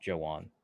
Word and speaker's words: Joejuan: I think it's just Joejuan. – Joejuan Williams? – Silent J. – Joejuan.